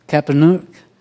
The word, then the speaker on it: Capernaum: How do you say that in English? Capernaum. Thank you.